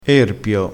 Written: erpio

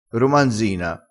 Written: romanzina

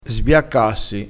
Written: sbiaccassi

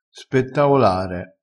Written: spettaolare

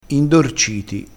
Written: indorciti

